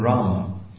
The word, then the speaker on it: Rama: Then Rama, the long A at the end, means the goddess of fortune, Rama Devi.